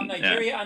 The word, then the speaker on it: Niger: You have Nigeria, and Nigeria is your neighbor, correct? – Yeah. We are Nigerians. They are Nigerians. – Right. – So it is pronounced Niger.